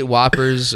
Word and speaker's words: Whoppers: I'll eat Whompers. I love a Whomper. – Whomper. – I said it wrong.